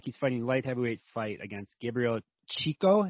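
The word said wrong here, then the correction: Checco: Is that how you pronounce it, Dan? – Checho. – Or is it Chechko? – Chechko. – Chechko, okay. Where is he from?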